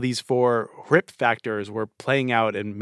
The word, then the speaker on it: RHIP: And so the mnemonic that I use with my students is R-H-I-P. Risk, habit, identity, power. – R-H-I-P. It's pronounced RIP. The H is silent.